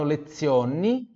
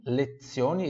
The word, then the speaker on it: lezioni: Se fanno lezioni, lezioni, esse Z também tem som de TS, igual pizza.